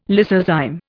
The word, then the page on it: lysozyme: Indian